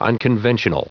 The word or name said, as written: unconventional